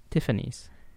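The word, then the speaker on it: Tiffany's: ティファニーズ。あ、そうなんですね。 S 発音するんですね。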